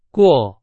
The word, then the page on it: 过: Chinese